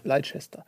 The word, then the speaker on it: Leicester: Leicester, wie es geschrieben wird, Leicester. aber nicht ausgesprochen ja. wird. Leicester.